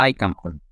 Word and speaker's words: Skeikampen: I'm in my apartment in Oslo now, trying to gather some energy and the rest before the action kicks off in at Skeikampen this weekend. – Skeikampen. Okay, so my pronunciation was pretty far off. – It wasn't too bad, but it's Shai to begin with instead of Ski. – Ah, Skeikampen.